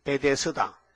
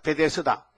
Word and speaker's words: 베데스다: 내가 베세다 물이니까. 말도 잘한데. 베데스다. 한번 해봐요. 베데스다. 아, 여러분 참 발음이 좋네.